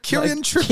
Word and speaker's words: Kieran Trippier: I butchered that name there. Do you know what? I was reading the name. – Kieran Trippier. Kieran Trippier.